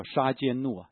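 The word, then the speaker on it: 杀 奸 怒 (צדקנו): 耶和华神是公义的神，叫杀奸怒啊，耶和华杀奸怒，如果讲发音的话，耶和华我们的义，他是一位公义的神。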